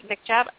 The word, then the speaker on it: hijab: So what's really the deal with the scarves and the burqas and the, is it the hijab? Is that how you pronounce the other one? Or okay, hijab.